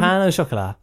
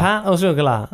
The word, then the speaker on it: pain au chocolat: How do you say it? Say it again. – Pan au chocolat. – Pan au chocolat. – Pan. So, I do pan.